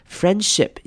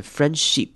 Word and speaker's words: friendship: Friendship 这个字要特别注意的是结尾的 i，记得要念成短母音 i 的声音，不要念成 friendship，而是念成 friendship，短母音 i 的声音。